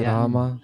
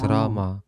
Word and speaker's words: Рама: Махарадж, один из моих духовных братьев сказал мне, что мы не должны говорить «Рама», слово «Рама», повторяя «Харе Кришна Махамантру». Он сказал, мы повторяем «Харе Рама», но он сказал, это не шудха, не чистое повторение.